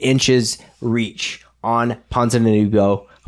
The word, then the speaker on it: Ponzinibbio: Ponzinibbio.